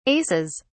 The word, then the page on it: aces: British English